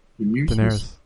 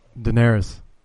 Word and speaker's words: Daenerys: How do you say her name? – Daenerys. – What is it? – Daenerys. – Daenerys, there you go.